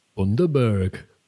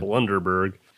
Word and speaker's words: Bundaberg: Like, it wasn't as sweet as a Bundaberg. – Thank you for the proper pronunciation. – Anytime. – Bundaberg.